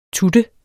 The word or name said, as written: tut